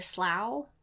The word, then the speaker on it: Slough: Near Slough? – Perfect!